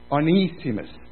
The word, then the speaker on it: Onesimus: And so you pronounce the E separately. Onesimus.